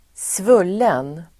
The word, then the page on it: svullen: [²sv'ul:en]